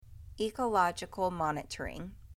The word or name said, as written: Ecological monitoring